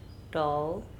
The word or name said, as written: Tou